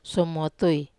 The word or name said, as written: somotui